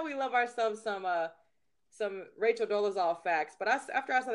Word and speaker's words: Dolezal: Dolezal.